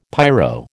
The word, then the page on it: pyro: American English